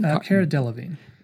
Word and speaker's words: Cara Delevingne: Cara Delevingne.